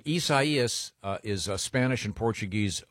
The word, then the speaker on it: Isaias: Said it's it's Isaias, and it's got three different. There's three different pronouncers here. – Yeah, yeah. – From the Associated Press, which is, it, there's like a Mexican, a French, and a Portuguese or something.